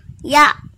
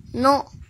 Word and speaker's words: 野: 和小一一起学日语第一百零七课，今日汉字，野外的野，音读的时候读作呀呀呀，比如蔬菜，野菜，野菜，野菜，写成日语汉字是野加菜，训读的时候读作 no no no。